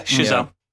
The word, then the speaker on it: Chazelle: is it Chazelle? Is that how I pronounce it? I don't want to... – Yeah, Chazelle.